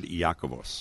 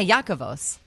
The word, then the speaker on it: Iakovos: How have you always pronounced it? – Oh, boy. Let me hear the way you... – I've always said and always heard Iakovos. – Yeah, Iakovos.